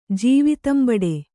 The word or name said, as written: ಜೀವಿತಂಬಡೆ